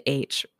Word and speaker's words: Aitch: So I'm going to start with a little town called H. And not the letter H. It's said H, but it's spelled A-I. T-C-H.